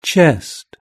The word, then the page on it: chest: British English